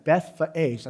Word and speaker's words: Bethphage: I actually looked up how to say that. Bethphage, that's how you say it in the Greek.